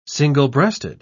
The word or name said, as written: single-breasted